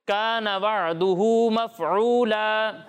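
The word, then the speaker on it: كان وعدا مفعولا: عین کو عین کے مخرج سے ہی ادا کریں گے, حمزہ کے تلفظ سے اسے نہیں پڑھیں گے. ٹھیک ہے. کان وعدہ مفعولا. – کان وعدہ مفعولا.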